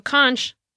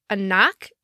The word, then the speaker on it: conch: And actually, there's two accepted pronunciations of it. So conch, like we would say, or a knock, if I'm saying that right.